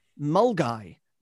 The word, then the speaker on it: Milngavie: I pronounced it as it looks, much to the amusement of everyone around me. Apparently, I should have known it was pronounced Mulgai, not Milngavie.